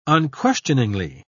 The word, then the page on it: unquestioningly: British English